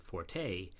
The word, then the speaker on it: forte: If you want to show you know where the strong point word comes from, say fort. But by now, so many people pronounce the word forte that we have to consider both pronunciations correct.